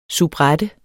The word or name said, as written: soubrette